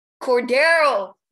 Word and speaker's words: Cordarrelle: Am I going to say this right? Cordarrelle Patterson. – Cordero. – Oh, my God. Okay. Cordero Patterson.